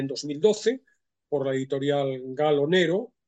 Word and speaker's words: Gallo Nero: Pronuncio la italiana porque la novela tiene un origen, digo, la editorial es italiana, la dueña de la editorial es italiana, aunque también hay quien lo pronuncia en castellano, Gallo Nero.